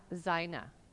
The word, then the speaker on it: zaina: The word for tactics is pronounced sanatha, And the word for armor is zaina.